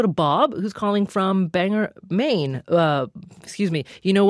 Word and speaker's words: Bangor: Well, Bangor is the right pronunciation, but...